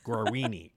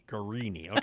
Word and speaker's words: Guarini: How do you pronounce his last name? – Guarini. – Guarini.